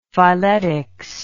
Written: phyletics

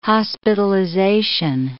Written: hospitalization

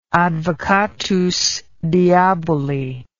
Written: advocatus diaboli

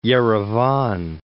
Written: Yerevan

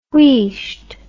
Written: wheesht

